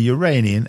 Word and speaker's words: Uranian: Uranian, and yes, that's how you say it, moons.